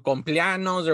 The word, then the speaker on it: cumpleaños: How do you say it? – Cumpleaños. – I can never say that. – You got to use your whole mouth. Dude. Cumpleaños.